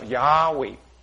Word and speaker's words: Yahweh: 但是如果我加两个元音字母，在 Y 后面加个 A，在 W 后面加个 E，就可以发音叫 Yahweh。